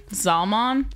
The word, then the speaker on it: Zalman: Zalman? How do you pronounce it properly? – Zalman. Yeah, but the way it's spelled, it probably sounds Zalman.